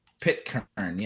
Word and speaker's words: Pitcairn: Pitcairn, yeah.